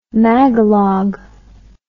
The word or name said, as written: magalog